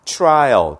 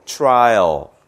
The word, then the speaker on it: trial: 발음할 때 조심해야 되는 건 이거를 트라고 읽으면 안 되고요. 추처럼 읽어야 돼서 trial 쫓아해보세요. trial, try, trial. 그러니까 우리가 나무를 저번에 트리라고 읽지 말라고 그랬죠. 추리라고 읽으라고 그랬잖아요. 그런 것처럼 tree, try, trial 이렇게 읽어주셔야 합니다.